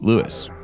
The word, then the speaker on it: Louis: He prefers his name to be pronounced Louis.